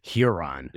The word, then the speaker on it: Huron: Huron.